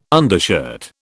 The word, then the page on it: undershirt: British English